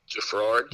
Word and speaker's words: Geffrard: Is that how you say it? Jaffard? – Jaffard, yeah. – Jaffard. Jaffard. Oh, there's a sneaky R in there. G-E-F-F-R-A-R-D.